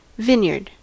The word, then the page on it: vineyard: American English